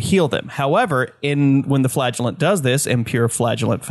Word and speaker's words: flagellant: They actually added a new hero to the game, the Flagellant. – Excuse me. Hold up. The flatulent? – No, not the flatulent. The flagellant. Soft flagellant. – Soft G. As in they flail themselves.